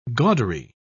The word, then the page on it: gaudery: British English